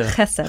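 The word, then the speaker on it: chesed (חסד): So if you've been pronouncing this as chesed for a long time, you can now change to chesed. – Chesed, yeah. – Chesed. Not clear chesed. The throat.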